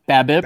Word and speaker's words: BABIP: BABIP.